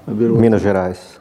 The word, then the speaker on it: Minas Gerais: Minas Gerais.